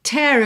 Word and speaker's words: tear: Lembra dessa pronúncia da palavra tear. Porque essa mesma escrita, T-E-A-R, também é uma outra palavra, lágrima, que se pronuncia tear, ok? Mas aqui é o verbo to tear, rasgar, ok?